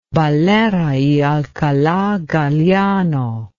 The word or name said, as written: Valera y Alcala Galiano